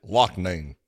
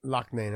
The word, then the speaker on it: Loughnane: How do you say his name? Lochnane? Lochnane? Lonane? Brendan? – Brendan Loughnane. – Loughnane, okay. Yeah.